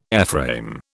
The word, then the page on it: airframe: British English